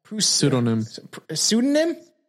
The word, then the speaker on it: pseudonym: Following his return, one boy who gave himself a Prudus, was that pseudonym? – Yeah, the P is silent.